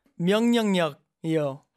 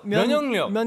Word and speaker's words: 면역력: 명령력이요. – 면역력.